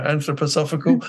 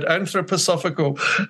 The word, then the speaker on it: anthroposophical: I've even learned how to say it now, anthroposophical. There you go. There's a development. I've got something from today. I'll now be very proud to say that word, anthroposophical.